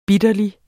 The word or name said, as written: bitterlig